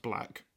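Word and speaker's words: 6lack: There's an artist that goes by the name of Black, and it's spelt six lack. It's pronounced Black.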